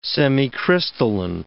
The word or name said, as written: semicrystalline